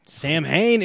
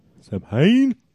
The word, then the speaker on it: Samhain: Sam Hain is in the building. – Sam Hain? Samhain? – It's fun to say it wrong. I think Sam it's Samhain.